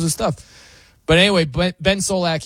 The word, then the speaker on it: Solak: So the most entertaining watches, according to Ben Solak, how do you say his name? Zolak.